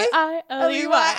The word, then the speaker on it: Hailey: And how you spell her name is H-A-I-L-E-Y. That was fun. H-A-I-L-E-Y. – Yeah, my grandpa, I learned how to spell my name with that song. – Really? – H-A-I-L-E-Y.